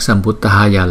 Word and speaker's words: Sambuddhaya: Chữ D và cái hơi nhiều một chút chúng ta đưa ra Samyak Sambuddhaya, Samyak Sambuddhaya.